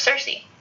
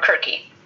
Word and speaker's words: Circe: For example, giving him an antidote to the spells of Circe, or Kirke, as it would be pronounced in Greek. But Circe sounds better, so that's what we're going to say.